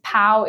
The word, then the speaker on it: Pão: And then there's another kind of local chain called Pão da Vila, which is Pau is Portuguese for bread. It's spelled P-A-O.